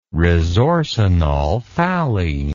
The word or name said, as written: resorcinolphthalein